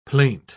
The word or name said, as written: plaint